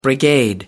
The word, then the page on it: brigade: American English